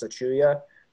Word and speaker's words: Achiuwa: The more and more tape I watch of that guy, Precious Achiuwa, is how you say his last name.